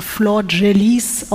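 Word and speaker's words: Flordelis: Ich weiß, es wird auch vielleicht ein bisschen komisch, weil der Name wird anders geschrieben, als er gesprochen wird. Also er wird Flor Delis oder Flor Delis geschrieben, aber Flor Jalice ausgesprochen.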